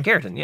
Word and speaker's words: keratin: Did I say that right? Keratin. – Yeah, keratin.